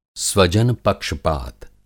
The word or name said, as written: स्वजन पक्षपात